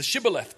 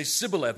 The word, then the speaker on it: Shibboleth: So this was the test, the Shibboleth test. And so they would say Sibboleth, and they'd say, all right, you know, because he couldn't pronounce it right.